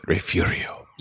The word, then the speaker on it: Refugio: Like it's spelled Refugio, but it is pronounced Refugio.